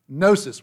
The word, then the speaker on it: gnosis: The Greek word knowledge is gnosis with a silent G on the front of it. So gnosis, so Gnosticism.